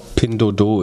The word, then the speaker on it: Pinduoduo: Eine Frage steht hier noch äh, für Pindudu. Habe ich das richtig ausgesprochen? – Ich glaube, Pindodo ist die richtige Aussprache.